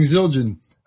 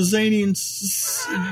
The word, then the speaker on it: Zildjian: And that he had a he had a difficult time pronouncing Zildjian, and often having to say, I left all the Zanians at the show yesterday.